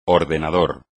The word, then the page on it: ordenador: espagnol